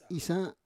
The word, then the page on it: isa: Roon